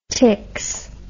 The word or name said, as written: ticks